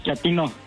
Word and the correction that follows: chatino: No, chatino. – Es como chato, ¿verdad? Chatino. – Sí, azunero como chato, chatino.